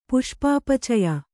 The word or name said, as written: ಪುಷ್ಪಾಪಚಯ